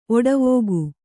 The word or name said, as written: ಓಟವಿಕ್ಕು